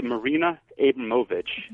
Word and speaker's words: Marina Abramovic: It's Marina Abramovic.